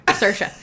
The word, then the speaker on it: Saoirse: And she goes, it's Saoirse, like inertia. – Oh, okay. – I was like, S-A-O-R-I-S-E.